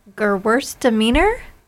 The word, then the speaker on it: Gewürztraminer: It reminds me of something I used to really enjoy when I was in my Prosecco phase, but it's sparkling, and I'm going to say the name wrong, but I will try. It's Gewürztraminer. – Yes, you said it. You said it very nice. Yeah, you said it nice.